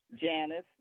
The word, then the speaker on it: Janice: Janice, J-A-N-I-C-E.